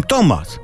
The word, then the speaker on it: Thomas: A tacy Angole piszą Thomas, a czytają Tomas. A gdzie H? Nie wymawiasz H, to nie pisz tego H.